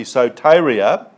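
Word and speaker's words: soteria (σωτηρία): Because the Greek word, it's soteria, if you really want to pronounce it properly, soteria, it means deliverance, preservation, prosperity, well-being.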